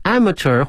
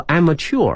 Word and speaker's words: amateur: amateur 或 amateur 啊，两个发音都可以， amateur 表示业余的意思， A-M-A-T-U-R amateur 可以 amateur。